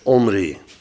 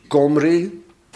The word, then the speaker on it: Omri: En Omri schrijf je met een ayin aan het begin. Dat is de eerste letter. En een ayin, dat is zo'n letter waarvan wij niet weten hoe we moeten uitspreken. Dus laten we hem gewoon weg. En we zeggen dus Omri. Maar als hij hard wordt uitgesproken, is het Komri. En als hij zacht wordt uitgesproken, is het Gomri.